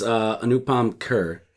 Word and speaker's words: Anupam Kher: It's Anupam Kher.